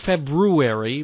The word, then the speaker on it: February: on this Monday, which, by the way, I pronounce it February, not Feb-U.